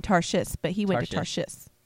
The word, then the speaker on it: Tarshish: And I think I said that town wrong. – What, Tarshish? – Did I say it right? – Yes.